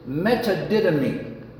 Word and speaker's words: metadidomi: But the word in the original language is metadidomi, okay? Phonetically, M-E-T-A-D-I-D-O-M-I, okay?